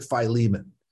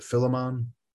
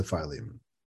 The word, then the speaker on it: Philemon: This is the letter of, I pronounce it Philemon. Sometimes people pronounce it Philemon, but I pronounce it Philemon.